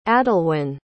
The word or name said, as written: adalwen